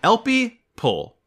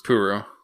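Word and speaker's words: Elpeo Ple: The Purple Qubeley's pilot, El El People, Elpie Pull is how you say it. Her first name is Elpie, last name is Pull. I thought her name was Puru. Puru or Pull, it goes either way.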